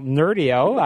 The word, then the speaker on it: Nerdio: I understand you just rolled out a new platform called Nerdio, or I'm not sure I'm pronouncing that correctly. – You are. – Okay, fantastic. Well, Nerdio, I like the name of that.